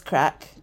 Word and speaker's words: craic: And it's spelled C R A I C. – C R A I. Oh, crike. – Yeah, but it's crack.